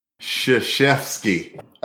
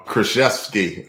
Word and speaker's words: Krzyzewski: Anyway, it was coached by Mike Krzyzewski. Did I say it right? – Krzyzewski. – Oh. – Just go with it. I don't know. Krzyzewski.